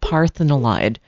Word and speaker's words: parthenolide: So if you take the roots of the Feverfew that's at least a three-year-old plant, you get this active component called parthenolide. So, that's spelled like the Greek Parthenon, except olide on the end, O-L-I-D-E.